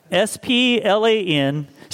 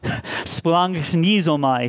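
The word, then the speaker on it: Splanchnizomai (σπλαγχνίζομαι): Splanchnizomai. Splanchnizomai. Splanchnizomai is when you click the little guy that helps you pronounce the word in the Greek concordance. That's how, well, that's sort of how he pronounced it.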